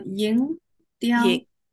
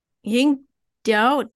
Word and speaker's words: Ying Diao: Ying Diao. – Ying Diao? – Yes. Okay. Yeah, that's good. Ying Diao.